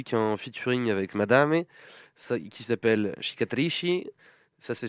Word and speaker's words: Madame: avec notamment une collab avec la rappeuse italienne Madame, dont Timothée avait déjà... – Du coup, tu le prononces beaucoup mieux, parce que j'avais dit Madame. – Madame, à la française.